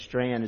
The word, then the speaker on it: Strachan: It's pronounced, spelled S-T-R-A-C-H-A-N, but Strand is pronounced.